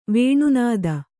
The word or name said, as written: ವೇಣುನಾದ